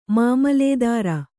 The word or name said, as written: ಮಾಮಲೇದಾರ